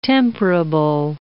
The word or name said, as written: temperable